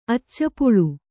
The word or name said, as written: ಅಚ್ಚಪುಳು